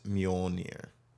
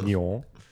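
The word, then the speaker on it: Mjölnir: It's Mjolnir. – Okay, good job. You're welcome. – Mjolnir. That's pretty cool. – So we have to ask Daisy about her new boyfriend if... – And where is Mjolnir is? – Yeah, if he carries one.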